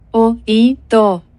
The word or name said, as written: ho he to